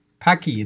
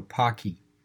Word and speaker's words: Paqui: Paki, it's spelled P-A-Q-U-I, but apparently it's pronounced like hockey, so Paki.